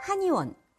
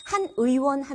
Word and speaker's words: 한의원: 그리고 한의원. 네, 침 맞거나 한약을 짓는 곳이 한의원이죠. 한 의원 하면은요, 의원이 한 개다가 돼버려요. 그러니까 한의원 이렇게 발음하셔야 됩니다.